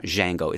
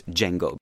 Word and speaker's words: Django: And that's why the name Django Reinhardt has a D because they pronounce it that way, Django. It's not Django, it's Django.